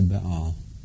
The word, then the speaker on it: Baal: "I have reserved for myself 7,000 who have not bowed the knee to Baal," or Baal, however you choose to pronounce that.